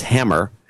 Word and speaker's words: Huemmer: And Tim Humer from Pelzer, South Carolina, wants a birthday shout-out to his sister and fellow producer Katie Humer, or it's pronounced Hammer. Sorry, H-U-E-M-M-E-R is pronounced Hammer.